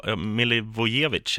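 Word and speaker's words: Milivojević: Men det man kan dra med sig här, det blev ju 3-1 då efter Miljovelit Miljo, Milivojevic heter han.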